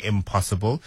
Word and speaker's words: I'm possible: I keep on saying impossible, it's spelled I-M apostrophe possible, so I'm possible, yeah.